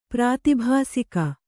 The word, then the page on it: ಪ್ರಾತಿಭಾಸಿಕ: prātibhāsika